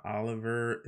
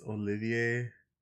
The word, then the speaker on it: Olivier: Also, it's not Oliver, it's Olivier Rothenstein.